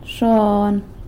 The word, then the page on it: xon: sanlucasquiavini